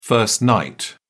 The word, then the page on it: first night: British English